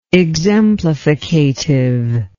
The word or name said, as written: exemplificative